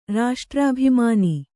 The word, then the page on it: ರಾಷ್ಟ್ರಾಭಿಮಾನ: raṣṭrābhimāna